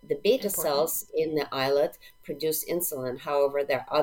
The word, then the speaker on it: islet: islet cell transplant. Islet, for everyone out there, is spelled I-S-L-E-T, like the tropical island, not E-Y-E, like the fabric.